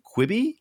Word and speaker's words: Quibi: Quibi. Is that how you pronounce it? – Yeah.